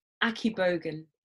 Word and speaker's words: Akigbogun: That's closer. Akigbogun. – Akigbogun. Okay. – Yeah, that's how I say it anyway. It's pronounced differently depending on... Yeah, that's how I pronounced it as someone who's lived all my life in London. I'm apologizing to all the people who will hear that and go, really? But that's how I pronounce it.